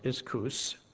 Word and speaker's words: ischus: It's ischus. I-S-C-H-U-S, pronounced iskous.